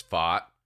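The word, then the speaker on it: Pfaadt: I want to give some love to PFAT for sure. – Yes, dude. – Like talk about showing up and like really letting everybody know how to pronounce it because it's FOT.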